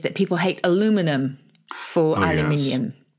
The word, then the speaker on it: aluminium: So it's spelt like magnesium and it's pronounced aluminium in the UK. Yes. But in America, it's aluminum and it's spelt like platinum.